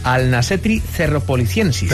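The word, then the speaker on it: Alnashetri cerropoliciensis: Se llama la especie, a ver si lo digo bien, Alnasetri cerropolisiensis.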